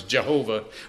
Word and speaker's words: Jehovah: In other words, when you hear Yahweh, or today you might hear it pronounced sometimes Jehovah, when you hear that name, remember I have promised.